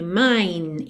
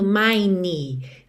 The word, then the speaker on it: mine: Atenção que o E de mine não é pronunciado. Então, às vezes, você se esforça muito para mostrar a diferença entre mine e my e acaba botando mine. Não pronuncie esse E, tá bem? A diferença é mesmo muito sutil. É só esse Nzinho dando acabamento. Se for M-Y, my, my. M-I-N-E, mine, mine.